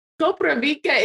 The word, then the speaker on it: Koprivica: Koprivica is the right name.